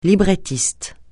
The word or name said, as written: librettiste